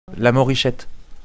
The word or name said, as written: Morichette